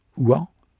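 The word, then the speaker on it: Ouen: Le trésorier du roi qui s'appelait Didier et un certain Odom, nom prononcé en contraction, Ouan, qui était nettement plus jeune qu'Éloi puisqu'il était né en 609 et n'avait donc que 19 ans lors de l'avènement de Dagobert.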